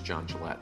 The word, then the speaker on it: Jon Gillette: My name is John Gillette.